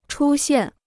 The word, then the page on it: 出现: chū xiàn